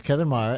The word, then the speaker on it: Maher: Kevin Maher, M-A-H-E-R, spelled like Bill Maher. Do you say it the same way? – It's pronounced the same way, yes.